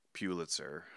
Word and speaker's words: Pulitzer: And every year when the Pulitzers get announced, Pulitzer, how do you say Pulitzer.